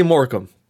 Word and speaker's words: Morecambe: And I know how to say Morecambe.